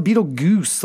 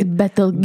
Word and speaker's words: Betelgeuse: And then um, yeah, it's like this classic like Vegas like neon sign, but it's spelled Beetlegoose, like be, like G E U S E, right? It's Betelgeuse.